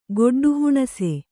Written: ಗೊಡ್ಡುಹುಣಸೆ

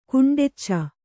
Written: ಕುಂಡೆಚ್ಚ

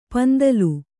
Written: ಪಿಂತಿಲ್

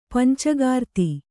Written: ಪಂಚೀಕೃತಿ

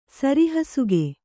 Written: ಸರಿಹಸುಗೆ

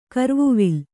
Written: ಕರ್ವುವಿಲ್ಲ

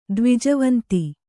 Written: ದ್ವಿಜವಂತಿ

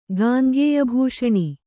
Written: ಗಾಂಗೇಯಭೂಷóಣಿ

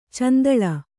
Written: ಚೆಂದುಳ್ಳೆ

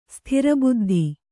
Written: ಸೂತ್ರಬದ್ಧ

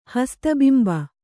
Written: ಹಸ್ತಬಿಂಬ

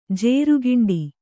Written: ಜಾರುಗುಂಡಿ

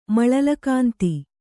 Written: ಮಳಲಕಾಂತಿ

